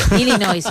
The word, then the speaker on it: Illinois: Y si uno busca ese nombre fonéticamente, es Illinois. Así que Illinois y la S, así que bravo, sin pronunciar la S final, Illinois, una bravo, vez más, bravo, tu apunte de bravo, geografía bravo, que bravo.